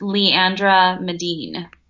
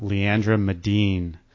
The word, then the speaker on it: Leandra Medine: How do you say her name again? – Leandra, and it's L-E-A-N-D-R-A, and then Medine, M-E-D-I-N-E. – Okay, Fashionista, Leandra Medine.